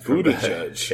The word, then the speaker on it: Buttigieg: Mayor Pete Buttigieg out of Indiana. – Nice pronunciation, Buttigieg.